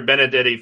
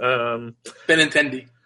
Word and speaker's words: Benintendi: Was that Benintendo? – I don't even know how to say his name. I say it really fast just to sound really smart. – Benintendi.